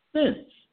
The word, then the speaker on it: cents: So if you try to make since plural, how would you pronounce it? Since.